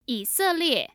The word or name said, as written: yiselie